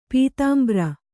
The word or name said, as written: ಪೀತಾಂಬ್ರ